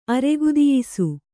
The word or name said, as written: ಅರೆಗುದಿಯಿಸು